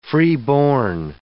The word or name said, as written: freeborn